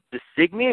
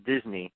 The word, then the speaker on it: Disney: And apparently, the original Irish pronunciation of the last name is Designey, if I'm saying that correctly, but it was anglicized to Disney when they moved here to the, the United States.